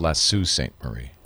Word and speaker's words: Sault Ste. Marie: That's pronounced Lasso Saint Marie.